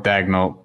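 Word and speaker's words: Daigneault: And, you know, shout out to, did I pronounce the right, Dagnett? Is that the coach's name, Mark Dagnett? – Mark Dagnett, yeah.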